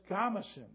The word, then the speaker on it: Gamason: And I said, "Well, it's Gamason." That's just like when you tell your kid to go mess up his room. "Go mess, son." And then you make it short.